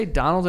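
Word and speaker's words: Domhnall: Did you say Donald in a weird accent? Or? – No, Donald. That's how you say his name. – Donal. – It's spelled Domnahal, but it's pronounced Donal.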